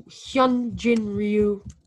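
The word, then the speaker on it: Hyun-jin Ryu: Hui Jin Ryu. I don't know how to say his name. – All right, Hyunjin Ryu.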